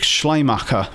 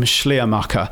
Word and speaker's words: Schleiermacher: And he traces it back to a German liberal church minister by the name of Friedrich Schleiermacher. I think that's how you say his name, Schleiermacher.